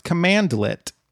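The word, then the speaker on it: cmdlet: Also, for the record, it's pronounced commandlet.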